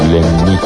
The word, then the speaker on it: Лянни: Квартира принадлежала его другу, манекенщику Лянни. С ударением на «и», уточнил он.